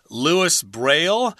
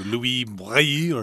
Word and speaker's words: Louis Braille: Louis Braille. – Something like that. Okay. But since we're speaking English here, we're going to stick to the English pronunciation of Louis Braille.